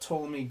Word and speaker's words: Ptolemy: I can remember how to pronounce it correctly, because the first letter is silent. Samuel Jackson. It's my beer. The Last Days of Ptolemy Gray, but Ptolemy starts with a P and then a T.